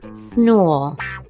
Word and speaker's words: snool: S-N-O-O-L. – Mm-hmm. – But it's pronounced snool. – Snool. – Okay. – I don't know, that might be my accent.